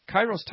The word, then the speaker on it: kairos: And in the time of harvest, that word time, translated in the Greek, is kairos. Forgive me if I didn't pronounce it right, but I'm pretty sure I'm right on with the pronunciation of that. Kairos. K-A-I-R-O-S.